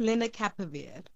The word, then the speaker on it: lenacapavir: So it's lenacapavir. – Lenacapavir.